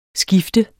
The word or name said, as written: skift